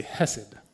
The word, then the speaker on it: hesed: So it's one of those clear your throat kind of Hebrew words. But it's spelled H-E-S-E-D. So I'm just going to say hesed, because because I'm American, I'm not going to do the sound, so hesed.